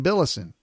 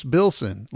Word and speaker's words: Bilson: Now, I was corrected by Paul Mulick, and he is 100% correct that I keep saying Billison. Now, I don't know where I'm getting those extra syllables in the words, but I am saying extra syllables. It's Bruce Bilson, like Wilson, Bruce Bilson, not Billison.